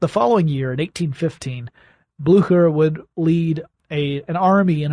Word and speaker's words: Blücher: I'm told that the Brits actually pronounce it Blücher, which makes sense because it's spelled B-L-U with an umlaut, C-H-E-R, the correct pronunciation if you're going with the German or Prussian, as it turns out, is more Blücher, but they were Blücher.